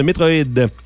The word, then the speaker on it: Metroid: Faut le prononcer comme en français. Metroid.